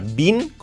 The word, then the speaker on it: been: B-E-E-N. Podemos pronunciar been con I o been. Un poco más, been. A mí me gusta más been.